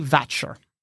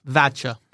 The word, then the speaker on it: Watcher: The name written beneath this is Lady Vatcher with a W. Vatcher, but with a W. – Lady Vatcher, but spelt Watcher? – Yeah, spelt Watcher, but with a, it would be pronounced Vatcher.